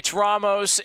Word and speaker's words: Ramos: It's Ramos. It's not. He's not one of the Ghostbusters. He's not Harold Ramos. It's Ramos. Very, very simple. Just think of Rom and then Os, and just go like that.